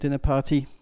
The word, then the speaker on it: Dinnerparty: Das ist das Schöne mit Dinnerparty, Betonung auf Dinner.